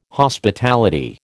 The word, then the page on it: Hospitality: American English